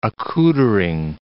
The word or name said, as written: accoutering